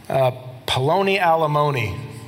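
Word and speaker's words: Peloni Almoni: And to illustrate this, the narrator names Boaz's brother Poloni Alimony. That's how you say it in Hebrew.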